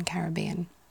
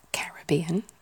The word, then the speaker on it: Caribbean: And because the British pronounce it as Caribbean rather than Caribbean. And according to, you know, laws of, I say laws, like generally speaking, rules of English language and pronunciation, obviously, as we know, there are so many exceptions, but we tend to put the emphasis on the vowel before a double consonant.